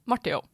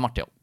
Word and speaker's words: Martey0: Hvordan uttaler du det? – Marte Jo. Det er Marte Jo. Det er det.